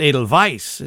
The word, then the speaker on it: Edelweiss: And so, I don't know, this is the, and it says Edelweiss, but we all know it's Edelweiss, is how you pronounce that, in Austria.